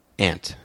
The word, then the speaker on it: aunt: A-U-N-T. – Ant. – You do ant.